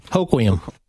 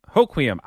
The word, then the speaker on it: Hoquiam: A story about the minivan in, I'm so sorry that I don't know how to pronounce this, Hoquiam? – Hoquiam. – Hoquiam.